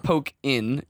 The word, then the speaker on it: Pokkén: It's not Pokkén. – It's Pokkén, like Tekken.